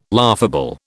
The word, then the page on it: laughable: British English